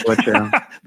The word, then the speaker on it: Necoech: I've only known two people in my whole life that could say my last name correctly. And it's spelled N-E-C-O-E-C-H.